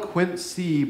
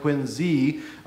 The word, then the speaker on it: Quincy: And there are, and with the city we lived in, which is spelled Quincy, but is actually pronounced Quincy with a Z, was a lot of immigrants coming in.